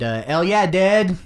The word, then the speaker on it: El Yeah Dead: We played with a band that actually reached out to us and asked us to come play, who are awesome, named El Yeah Dead. That's how you say it. It's ELLEYEHDEHD, and they corrected my pronunciation.